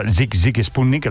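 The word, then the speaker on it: Sigue Sigue Sputnik: Sí, lo que pasa que era Zig Zig Sputnik, la pronunciación, y el tema Love Missile F1.11.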